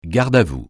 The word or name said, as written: garde-à-vous